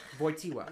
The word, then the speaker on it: Wojtyła: Like, so Karol Wojtyła is spelled K-A-R-O-L. W-O-J-T-Y-L-A. – Wojtyła.